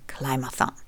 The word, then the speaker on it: Climathon: Aus dem Englischen ist das Wort Climate für Klima zusammengesetzt mit dem Wort Marathon, hier aber wieder englisch ausgesprochen, also Marathon. Und die Worte zusammengesetzt ergeben Climathon.